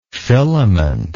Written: filamented

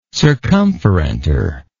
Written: circumferentor

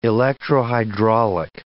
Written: electrohydraulic

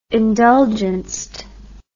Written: indulgenced

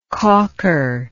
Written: caulker

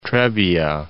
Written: Trebbia

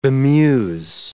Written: bemuse

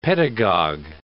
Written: pedagogue

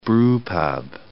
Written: brewpub

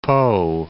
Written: Po

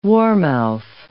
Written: warmouth

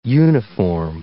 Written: uniform

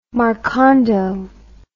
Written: marcando

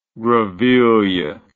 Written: greville

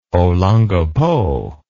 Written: olongapo

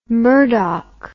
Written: Murdock

